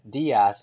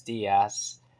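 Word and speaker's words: Díaz: And same thing with this, but it, what I'm trying to say is that that Hispanics don't pronounce the Z correctly, so they pronounce it like an S, So they would say Moises Diaz. And when you say it like that, Diaz is the same thing as literally, if you change the Z to an S, that literally means days in Spanish.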